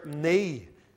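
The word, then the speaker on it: Ni: And there was a man that lived not far from Hudson Taylor's little house, and his name was Mr. Nee, and that's spelled N-I, Mr. Nee.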